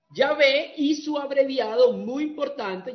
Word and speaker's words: Yahvé: Porque Yahvé, algunos lo pronuncian Yahweh, porque la Bau la leen como W, pero nosotros hicimos el ejercicio, y Dawi no suena bien, suena el David con V. Entonces uno lo leen como Yahvé, otro lo leen como Yahweh.